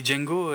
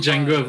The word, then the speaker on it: Django: Oui, Django, with Django, voilà, et il lui dit le, le D ne, se prononce pas, et l'autre lui répond, je sais.